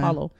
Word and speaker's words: Paolo: Oh, did you say Apollo? I said Apollo. Do that. People do that all the time. Paulo. – P-A-U-L-O. – P-A-O-L-O. – P-A-O. – Yes. Sometimes it is spelled P-A-U, though. – Okay. Okay. Yeah. Paulo. Okay. – Yeah, it's an Italian name.